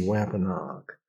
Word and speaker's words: Wampanoag: Wampanoag, Wampanoag, W-A-M-P-A-N-O-A-G, Wampanoag, was the tribe of people that, here's what they did.